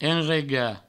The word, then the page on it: enregar: enrega; provençal